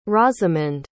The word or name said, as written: rosamond